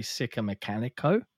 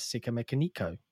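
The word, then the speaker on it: Sikamikanico: I say Sika Mechanico. A lot of people just call me Sick. But yeah, in the Red Hot Chili Peppers song, they do indeed pronounce it as Sika Mechanico.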